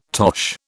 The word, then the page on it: tosh: British English